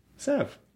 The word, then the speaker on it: salve: I think it's sove. – How do you spell it? S-A-L-V-E. – Yep. – Salve. – Spoken like a true spelling bee champion. – Yeah, I think the L is silent.